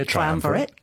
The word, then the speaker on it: triumvirate: We were talking, you used the word, which I've never heard in my life before, so casually, triumvirate. – Yes. – Is that how you say it? – Yeah, triumvirate.